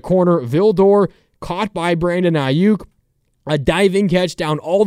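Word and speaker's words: Aiyuk: Say it right. Ayuk! Ayuk! Ayuk!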